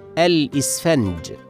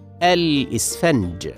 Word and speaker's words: الإسفنج: من الكلمات المتداولة على الألسنة كلمة الإسفنج بكسر الهمزة وسكون السين وفتح الفاء الإسفنج ولكن البعض ينطقها بفتح الهمزة وكسر الفاء وهذا خطأ شائع والصواب نطقها هكذا الإسفنج